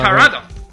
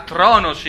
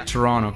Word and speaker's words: Toronto: A Trono Trono. – A, ma, scusa, ma perché si scrive Toronto? – È eh, la pronuncia Tor- local, Trono. Ottawa e Toronto.